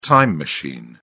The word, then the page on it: time machine: British English